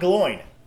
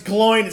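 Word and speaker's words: Glóin: My name's Gloin. It's pronounced like groin. Ugh.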